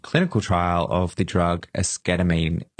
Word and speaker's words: esketamine: And esketamine, it sounds like the word ketamine because it is ketamine.